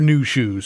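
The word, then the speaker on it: Nu Shooz: Now, if you didn't know the exact 80s-ness of New Shoes, you just got to be aware that The name of their band is spelled N-U, then S-H-O-O-Z, for New Shoes.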